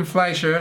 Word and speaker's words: Fleischer: Hardy will star as Eddie Brock with Ruben Fleischer.